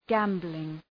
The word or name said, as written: gambling